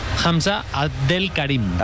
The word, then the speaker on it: Hamza Abdelkarim: ¿Cómo se pronuncia? – Hamza Abdelkarim. – Marquines, podríamos comprobar en la página de FIFA si se pronuncia como ha hecho. – Será al revés, pero bueno, se puede comprobar, ¿eh? No, nos quedamos con este audio y luego miramos cómo se dice. – Lo ha pronunciado perfecto, el talento, el joven talento del Barça.